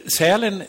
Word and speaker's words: Sälen: Sälen, det tänker man inte på. Nu säger jag det som det egentligen ska uttalas. Man tänker inte på att man åker, det har inte med djuret att göra. Utan det betyder ju sel, som betyder fäbod.